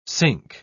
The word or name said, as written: synch